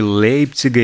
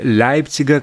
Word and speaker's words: Лейпцига: Э, такой традиционный немецкий пенный напиток типа Эля производится в районе Лейпцига или Лейпцига, как кому ближе произносить,